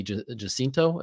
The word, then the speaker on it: Jacinto: But then, of course, one of my favorites is I think I mentioned on a past episode was Manny Jacinto, I think is how you say his last name. Yes.